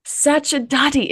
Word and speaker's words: Sacerdoti: Sacerdoti.